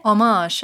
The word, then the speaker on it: homage: Homage, I'd say. It's silent homage. Ancient French, isn't it? – I've been saying homage my whole life.